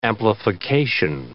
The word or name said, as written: amplification